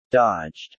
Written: dodged

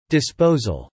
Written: disposal

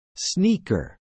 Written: sneaker

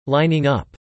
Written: lining up

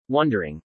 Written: wondering

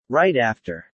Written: right after

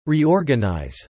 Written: reorganize

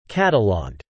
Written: cataloged

